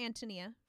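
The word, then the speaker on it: Ántonia: Yeah, it's pronounced Antonia for those of you who've seen the title and thought it was My Antonia.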